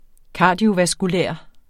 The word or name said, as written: kardiovaskulær